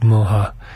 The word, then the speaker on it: MYXA: So it's actually spelled M-Y-X-A. It's a Ukrainian word, so I guess it's pronounced Moha.